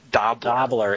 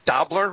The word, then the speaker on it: Dobler: Dobler and Diane Court. – Like rhymes with cobbler. Dobler, really? – It's right.